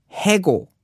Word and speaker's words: 해고: But the proper way to say it would be hego.